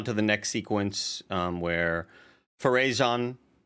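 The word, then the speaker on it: Pharazôn: Farazan, you put the acento at the final syllable.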